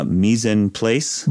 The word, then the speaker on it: mise en place: Yeah, there's this, I love this idea and it's reminding me of a cooking thing that I'm going to horribly mispronounce because it's French, mise en place. – Mise en place.